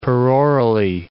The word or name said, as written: perorally